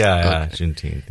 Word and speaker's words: Juneteenth: I can't pronounce that word. Juneteenth? Is that how you pronounce it? – Juneteenth.